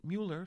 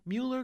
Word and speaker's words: Müller: It's M-U-L-L-E-R, and that U has an umlaut over it, so you say it Mueller, something like that. Mueller glia, G-L-I-A.